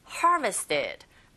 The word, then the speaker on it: harvested: harvested. 앞부분에 강세를 꽝 주시면 됩니다.